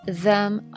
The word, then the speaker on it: them: 那么这边很有意思，leave them on。那么 them 在口语中的时候，跟如果它前面这个单词结尾是辅音的话，th 通常是不发音的。